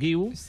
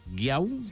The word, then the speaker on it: Guiu: I, clar, tothom va parlar de Marc Guiu, aquí cap problema, però han tingut problema les Espanyes en pronunciar correctament Guiu. Marc Guiau, Marc Iu, Marc Gui, i Marc Giu.